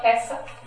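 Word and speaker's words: hesed (חסד): It's pronounced what? – Hesed? Yeah, hesed. H-E-S-E-D.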